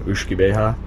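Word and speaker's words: uisge beatha: So whiskey actually came from the Gaelic term ushkibeha. That's the Scottish Gaelic pronunciation, which means water of life.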